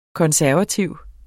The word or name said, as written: konservativ